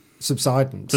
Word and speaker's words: subsidence: But it was Ashley's idea that the house had subsistence. – Right. – Subsistence, sorry. – Subsidence. – Subsidence. Well, yes, but no, subsidence, the common pronunciation.